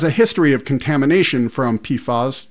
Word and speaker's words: PFAS: Federal environmental officials host a webinar this week to discuss the Environmental Protection Agency's proposed new limits on dangerous substances called PFAS, pronounced PFAS, in the state's drinking water.